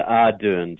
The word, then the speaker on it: Ardern: Is it pronounced Ardern? – Yeah, Ardern. Jacinda.